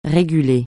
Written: réguler

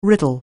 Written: riddle

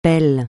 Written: beau

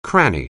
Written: cranny

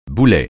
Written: boulet

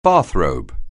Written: bathrobe

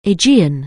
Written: Aegean

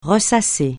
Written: ressasser

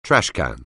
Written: trashcan

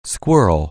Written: squirm

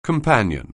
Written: companion

